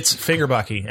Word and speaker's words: Fagerbakke: I thought he's from Bill Fagerbaker? Fagerhaven? – It's Fagerbakke, actually. I just learned that the other day. That's how you pronounce it.